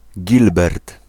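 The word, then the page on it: Gilbert: Polish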